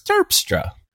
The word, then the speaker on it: Terpstra: Isn't it Terpstra? I can't do it. – No, it's on the first syllable. It's Terpstra.